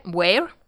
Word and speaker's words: ver: So spring is V-E-R, where. – Yeah. – You know, with the V pronounced as W.